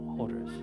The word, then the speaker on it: hoarders: Que creo que es Horden. Horden. Horden. – Horden. How you pronounce that? Hoarders. Hoarder.